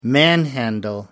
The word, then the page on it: manhandle: American English